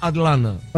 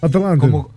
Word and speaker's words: Atlanta: Acá si lo querés pronunciar bien, tenés que decir Atlanta. – Atlanta. – Como consumiéndote el ta, Atlanta. – Claro, casi que es un na, Atlanta.